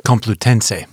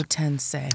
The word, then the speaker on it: Complutense: He has his BS in marketing, in business administration and marketing, and he also studied at the University of Madrid at Complutense. Did I say that right? – Complutense. You were close. – Complutense.